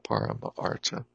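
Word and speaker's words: paramartha: And when they join the A that ends the word parama in the beginning of artha, combine and they make a long A. Parama artha means the goal sought by those who strive for what is excellent.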